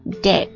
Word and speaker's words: debt: he began to slip into debt，注意一下这个债务的单词啊，它这个 b 是不发音的，直接读作 debt。